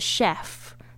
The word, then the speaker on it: chef: chef 大家特别注意一下那个 ch 讲很多次这个是很特殊的发音是 sh 的声音 chef 不要念成 chief